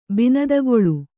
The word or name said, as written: ಬಿನದಗೊಳು